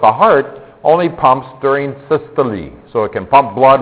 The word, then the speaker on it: systole: They're called systole, and the second one is diastole. That's how it's pronounced.